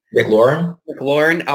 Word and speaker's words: McLaurin: McLaurin out in Washington.